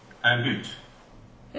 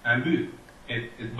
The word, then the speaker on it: but: Un but. – Interesting. – However, you also have the option to not pronounce it. Un but. Both are equally appropriate.